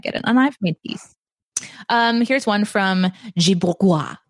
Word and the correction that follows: Bourgeois: I don't think I said that right. In fact, I know I didn't, and I apologize. I was just trying to have fun. – Bourgeois.